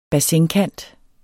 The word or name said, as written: BASSIN